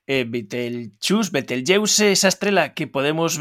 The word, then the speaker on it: Betelgeuse: a pronunciación segue sendo como ese árabe original Betelgeuse ou Betelgeuse en castelán. En galego creo que poderíamos dicir Betel Betelgeuse ou Betelxeuse. Creo que serían as dúas formas máis idóneas ou máis respectuosas, non creo que coa etimoloxía para nomear a esta estrela.